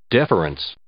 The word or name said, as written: deference